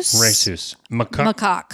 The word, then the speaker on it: rhesus macaque: Rhesus macaque.